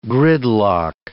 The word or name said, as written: gridlock